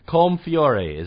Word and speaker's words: Colm Feore: And Colm Feore is his name. That's how it's pronounced.